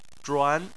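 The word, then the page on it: zhuan: tone 2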